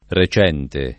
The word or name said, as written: recente